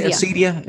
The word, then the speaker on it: acedia: Am I pronouncing that word right? Do we say acedia? – Yeah. Yep, that's how I say it anyway.